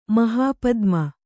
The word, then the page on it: ಮಹಾಪದ್ಮ: mahāpadma